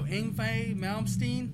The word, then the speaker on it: Yngwie Malmsteen: No, Yngwie Malmsteen? – Yeah. – Am I saying that right? – Yeah.